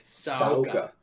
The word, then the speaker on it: Saca: And that is the, how do you pronounce the name of the new Hookajan hookah? Soaka? – S-A-C-A. Soaka.